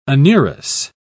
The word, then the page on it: anurous: British English